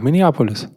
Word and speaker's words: Minneapolis: Warum kann ich eigentlich Minneapolis nicht aussprechen? Mini, Minneapo, Minneapolis heißt das, ne? Ja, Minneapolis. Minneapolis.